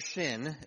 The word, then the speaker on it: Sin: We read this, they set out from Elim, and all the congregation of the people of Israel came to the wilderness of Sin, or Shin, it could be pronounced as well, which is between Elim and Sinai, on the 15th day of the second month after they parted from the land of Egypt.